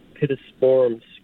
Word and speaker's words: Pittosporum: I've got a bunch of Pittosporum screen masters.